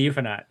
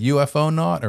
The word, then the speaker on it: Euphonaut: of the Euphonaut. – Yeah. I was like, is it UFO not or what? But it's Euphonaut.